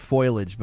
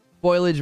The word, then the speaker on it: foliage: The pronunciation is disapproved because it suggests the transposition of the I in the spelling, the I and the L in the spelling. It's not as common and may be associated with non-standard spelling, foliage.